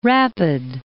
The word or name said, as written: Rapid City